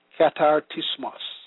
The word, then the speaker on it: katartismos: In the Greek, perfecting is pronounced katartismos, meaning complete furnishing.